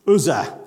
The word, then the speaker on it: Uzzah: Uzzah. Uzzah, that's what I thought it was, but it just doesn't sound right.